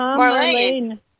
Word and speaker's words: Marlene: Is that Marlene? How do you say that, sir? – Yes, it is. Uh-huh, Marlene.